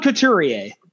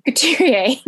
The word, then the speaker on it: Couturier: Sean Couturier. – Couturier.